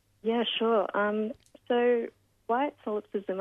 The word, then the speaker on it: solipsism: And you also um, mentioned white, I don't know if I'm going to pronounce this correctly, solipsism? – Yeah, that's right.